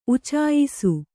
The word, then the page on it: ಉಚ್ಚಾಯಿಸು: uccāyisu